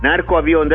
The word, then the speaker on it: narco avión: Narco avión, that's how you say it.